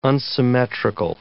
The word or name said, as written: unsymmetrical